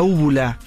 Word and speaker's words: úvula: La úvula. – Úvula. – Úvula, con U, acento en la U y B corta. Úvula.